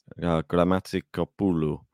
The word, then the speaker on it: Gramatikopoulou: Gramatikopoulou.